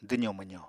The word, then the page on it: dwzo-mwzo: apatani